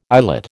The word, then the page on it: eyelet: American English